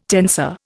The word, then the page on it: tensor: Indian